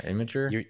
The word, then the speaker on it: Imgur: So it's like you were cold emailing the guy from, I think it's Imgur, or how do you pronounce that? – Yeah. – Imgur? I don't know. – Yeah, Imgur.